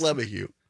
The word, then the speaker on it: LeMahieu: I don't know how it's pronounced. – It's LeMahieu. But it's just like I heard LeMahieu a bunch.